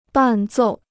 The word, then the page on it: 伴奏: bàn zòu